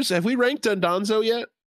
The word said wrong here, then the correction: Dondozo: Or am I is it Dondozo? Oh, it's Dondozo. I've been saying it incorrectly.